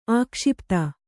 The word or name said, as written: ಆಕ್ಷೇಪಿತ